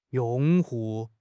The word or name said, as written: 用户